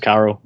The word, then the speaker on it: Currell: Oh, just Currell with a U.